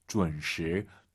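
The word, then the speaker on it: 准时: UN 사이에 어 들어가야 돼요. 준, 준시, 준시 쉽지 않습니다. UN 발음을 어려워하시면은요, 결혼하다를 떠올리세요. 결혼하다, 지의 훈 아니죠? 시작. – 结婚. – 혼, 혼, 그렇죠. 혼을 담아서 지의 훈 하시면 좋을 것 같습니다.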